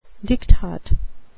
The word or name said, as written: diktat